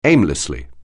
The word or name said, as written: aimlessly